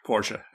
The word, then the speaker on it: Porsche: Like the family prefers Porsche. – Porsche. Okay. I just didn't see the A at the end of it. Well, it's pronounced A. – It's pronounced. It's not there, but it's pronounced.